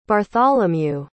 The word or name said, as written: bartholemew